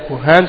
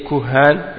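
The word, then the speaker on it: kuhan (كهان): Et on dit pour la, ça c'est le pluriel, kuhan c'est le pluriel. Le singulier c'est kahin. Hein? Donc kuhan, kahin. Kahin ça s'écrit kaf, alif, ha. Kahin, c'est ça. Kaf, alif, ha, nun. Kahin. Et kuhan, eh bien, c'est le pluriel.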